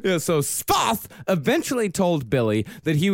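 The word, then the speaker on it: Sfath: Svoth. – Svoth. It's important to put all of the letters together in Swiss. – How do you Svoth.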